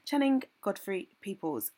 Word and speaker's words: Channing Godfrey Peoples: Channing Godfrey Peoples.